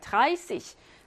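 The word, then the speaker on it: dreißig: 30은요. 예외예요. 왜 예외냐면 원래 찌시여야 하잖아. 근데 어떻게 했어? S챗이 있죠. 씨시로 발음해요. 드라이씨시.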